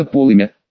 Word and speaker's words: полымя: А что касается полымя, то в другом труде Даля, толковом словаре, дано такое определение. Полымя, и ударение тут ставится на первый слог.